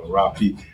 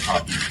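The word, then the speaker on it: rapé: And with it's usually R-A-P-E with a little apostrophe thing on there, no Rapi. But some people call it, it's usually pronounced as Hoppy.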